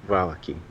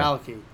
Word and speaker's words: Velaki: And like we said earlier, either the Abbey of St. Markovia or the town of Velaki? Velikai? Velikai. – Velaki. – Yeah. Velaki. I try to do it phonetically.